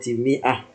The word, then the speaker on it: مئة: تزاد الألف وسطا في كلمة مئة. في كلمة مئة. والقراءة تدل على هذه الزيادة للألف لأنك ما تنطق بإيش بالألف قبل الألف كسرة. قبل الألف كسرة ولا تنطق بالألف فدل ذلك على زيادة الألف.